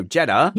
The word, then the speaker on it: Jeddah: So, tell me how to say it again. – Jeddah. – What did I say? – Yeah, like cheddar cheese. – Okay, so Jeddah, right, we will get a glimpse of how that will be.